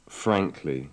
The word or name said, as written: frankly